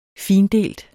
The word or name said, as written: findelt